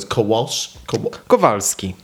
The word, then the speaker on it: Kowalski: And how do we say that name? So it's Kowalski. – Kowalski. – Yes.